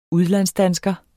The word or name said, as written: udlandsdansker